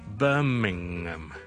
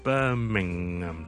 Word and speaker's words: Birmingham: B-I-R-M-I-N-G Birming 之後 H-A-M，很多人呢見到 H-A-M 就會讀成 Ham Ham Ham 讀埋個 H-A-M，但是呢其實很多時候呢，我們呢在地名裡面的 H-A-M 呢就未必會讀個 H 的，那我們就讀成 Birmingham Birmingham，聽到了 Mingham。